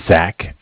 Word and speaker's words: Zach: Yeah, my name is Zach, spelled Z-A-C-H.